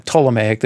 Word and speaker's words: Ptolemaic: Ptolemaic, you don't pronounce the P.